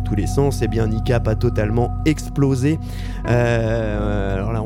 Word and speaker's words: Kneecap: Il est le coup de cœur de la semaine, qui, cette semaine, ne pouvait être autre que le nouvel album de Niecap. Alors, ça s'écrit K-N-E-E-C-A-P.